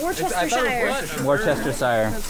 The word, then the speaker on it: Worcestershire: Worcestershire. – Because why is there a Shire there? – Ask Rachel would know. – Shire is sure in the UK.